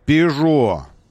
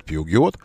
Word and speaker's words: Peugeot: И еще один французский автомобиль — Peugeot. Отставить говорить неправильно, правильно говорить Peugeot.